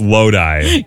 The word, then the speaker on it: Lodi: It's L-O-D-I. How do you say that? – Yes, I now know that it's Lodi.